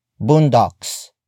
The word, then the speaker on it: boondocks: Boondocks. It is spelled as B O O N D O C K S.